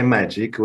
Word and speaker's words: Magic: I typically go by Magic, which is actually the closest to the way how it should be pronounced.